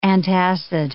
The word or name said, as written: antacid